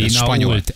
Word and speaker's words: Pinault: Nem tudom, hogy ejtik, mert nem beszélek franciául. – Pinya. – Pinya. – Pinya. – Pinya. Pinya colada. – Pinault, ez a neve. – Ja, nem spanyol? – Nem, francia.